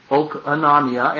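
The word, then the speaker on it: okonomia (οἰκονομία): I'm trying not to pronounce it okonomia because it is an omicron. All those O's are omicrons and not omegas. Okonomia.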